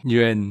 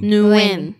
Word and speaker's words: Nguyen: How do you pronounce that? – Nguyen. – Nguyen. – Yeah.